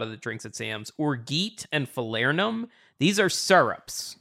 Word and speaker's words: orgeat: I will correct you only because I wanted to compliment that item, so I looked up the pronunciation, and it is orgeat. – It's orgeat.